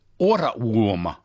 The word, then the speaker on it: Ohrwurm: is a translation from German, which is Ohrwurm. I hope I've got that pronunciation right. It's O-H. Ohrwurm, which has exactly the same meaning.